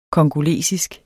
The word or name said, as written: congolesisk